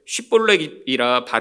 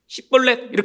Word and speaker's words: 십볼렛: 히브리어에 쉬와 씨가 있습니다. 근데 같은 단어인데 이들이 발음을 못하는 거예요. 이 좁은 나라에서도 이 사투리가 생겼나 봅니다. 그리고 한 단어를 가지고 한번 발음해봐. 그런데 그들이 씨벌렛 이렇게 하면 오쉬 어, 해봐 쉬 이게 안 되잖아요.